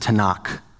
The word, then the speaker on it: Tanakh: And the acronym is TNK. They often pronounce it Tanakh.